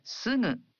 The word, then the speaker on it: すぐ: In Japanese, you pronounce gu in the initial syllables of words as a voiced gu, like ga, gi, gu, ge, go. But for other syllables, such as sugu, you pronounce the gu sound as a nasal.